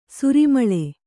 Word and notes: ಸರಿಮಳೆ: sari maḷe

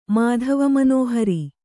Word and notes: ಮಾಧವಮನೋಹರಿ: kannada

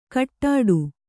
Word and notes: ಕಟ್ಟಾಡು: kaṭṭāḍu

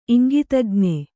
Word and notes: ಇಂಗಿತಜ್ಞೆ: iŋgitajñe

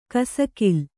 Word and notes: ಕಸಕಿಲ್: kasakil

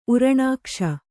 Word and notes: ಊರ್ಣಕೇಶ: ūrṇakēśa

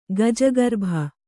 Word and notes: ಗಜಗರ್ಭ: gajagarbha

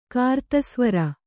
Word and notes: ಕಾರ್ತಸ್ವರ: kartasvara

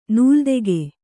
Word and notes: ನೂಲ್ದೆಗೆ: nūldege

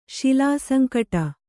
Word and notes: ಶಿಲಾಸಂಕಟ: kannada

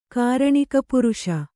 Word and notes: ಕಾರಣಿಕಪುರುಷ: Kannada

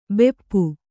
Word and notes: ಭಾಪು: bhāpu; Kannada

